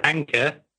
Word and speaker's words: Anker: Anker.